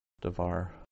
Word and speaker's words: devar: So, in devar, word of, the first vowel has been shortened from a long A to a short shva, devar.